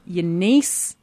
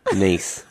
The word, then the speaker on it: gneiss: your gneiss with a silent G. – Oh, yeah, gneiss with a silent G at the beginning, like gnome. – Like gnome, yeah.